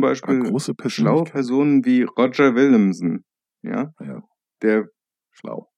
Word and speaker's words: Roger: Iroga, ja, Roger. Nein, genauso wie ein John Willemsen, John Willemsen ist. Alter Hamburger Tradition, englische Vornamen äh, nehmen, aber deutsch aussprechen.